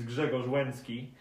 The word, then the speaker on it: Grzegorz Łęcki: So my name is Greg Lecki, and I like to say that my real name is Grzegorz Łęcki, but this is Polish and not very many people can say it correctly, so I usually go by Greg.